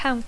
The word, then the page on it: count: English